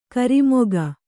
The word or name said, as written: ಕರಿಮುಖ